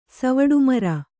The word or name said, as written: ಸವಡುಮರ